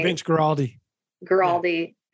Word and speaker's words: Guaraldi: Vince Guaraldi.